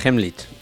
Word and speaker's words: Heimlich: La maniobra de Heimlich.